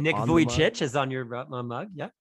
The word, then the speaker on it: Vujicic: But, so you know, here's Nick the best way to pronounce his name. It's kind of like the word voyage, Nick Vujicic.